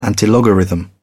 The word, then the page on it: antilogarithm: British English